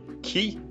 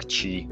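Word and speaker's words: Qi: Причем, что не прикольно, называется вроде Key, то есть буква Q и I. Это устройство от э, фирмы Texas Instrument, а читается как Chi, такая энергия Chi внутренняя.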